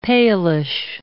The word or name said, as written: palish